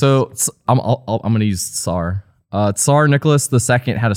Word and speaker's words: Tsar: Now, do you pronounce Tsar with a C or with a T? I prefer Tsar. The T. Tsar.